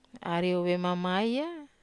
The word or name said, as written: ariou vemamaya